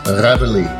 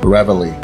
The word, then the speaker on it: Reveille: You say it Reveille. – Reveille? – Reveille.